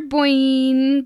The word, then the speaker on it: Boynton: Dr. Petra Boynton. Think. Sure. – Nailed it. – Yep. Boink. Rhymes with boink, boink, boink, boink, boink.